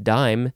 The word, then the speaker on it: disme: I guess they pronounced it dime spelled D I S M E um yeah, interesting.